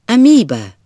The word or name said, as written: amoeba